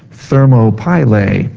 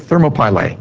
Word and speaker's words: Thermopylae: Thermopylae. Pretty good. Thermopylae.